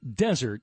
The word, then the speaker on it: Desert: It's spelled dessert, but it's pronounced desert.